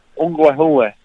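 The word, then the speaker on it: Onkwehonwe: Is it pronounced Ungwehwe? – Yep, Ungwehwe. – Okay. – You can really exaggerate the E's there. – Okay. – Ungwehwe.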